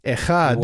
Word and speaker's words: echad: But the word for one here in Hebrew, and when I pronounce it's going to sound like I'm hawking a loogie because Hebrew generally sounds like that, but echad.